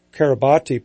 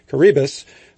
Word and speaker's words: Kiribati: As part of this research, Dr. Donner maintains a very interesting monitoring program in the Pacific Island country of Kiribati, or if you read the word, you might think it's pronounced Kiribati, perhaps, but it's pronounced Kiribati.